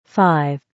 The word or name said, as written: 5